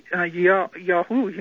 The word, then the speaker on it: Yahu: And also, just as Yahweh, praise his name, is shortened to Yah, and there's no J, by the way, in Hebrew. So it's not Jew, the hard J. It's Yahu, you might say.